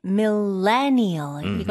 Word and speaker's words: millennial: 네, 밀레니얼. 이거는 강세에 신경 써주세요. 레 이렇게 강하게 해주시면 돼요. 밀레니얼.